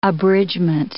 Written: abridgment

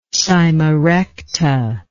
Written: cyma recta

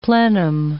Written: plenum

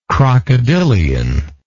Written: crocodilian

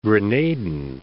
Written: Grenadan

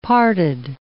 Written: parted

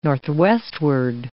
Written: northwestward

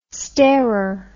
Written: starer

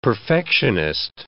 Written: perfectionist

